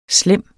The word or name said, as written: slem